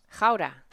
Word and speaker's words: Gouda: Except it's not pronounced Gouda over there. What is it? What's it pronounced? – Houda.